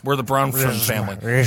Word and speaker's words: Bronfman: It's Bronfman. And I'll tell you what it is. It's that F. They stick right in the middle. Bronman? Got it. Bronfman.